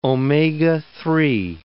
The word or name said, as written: omega-3